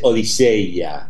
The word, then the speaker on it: Odisea: La que es más culturosa, es que es como se pronuncia en griego. Entonces es Odiseia.